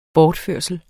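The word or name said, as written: Bortførslen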